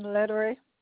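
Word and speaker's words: Lederrey: Yeah, Marianne Lettery.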